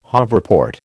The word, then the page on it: hoverport: American English